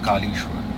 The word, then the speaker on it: Kalieschwar: It's like Kali and Ishwar, so it's Kalieschwar.